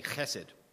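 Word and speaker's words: chesed (חסד): It's like a hard J, chesed.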